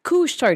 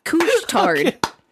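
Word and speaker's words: Couche-Tard: Couch tard, because I'm thinking douche. It's spelled like douche with okay. a C. Couch tard.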